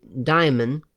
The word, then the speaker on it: daemon: daemon, D-A-E-M-O-N, or diphthong, D-A-E, diphthong, M-O-N, is pronounced diamond, it would have to be diamond, and is a Greek word for an entity with demigod abilities, and it has neither a good nor evil connotation.